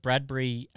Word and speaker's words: Bradbury: Yeah, I know we mentioned maybe bat sponsors were out of the equation, but have we mentioned this week that Bradbury actually rhymes with Cadbury? – And it's a real Tasmanian connection, isn't it?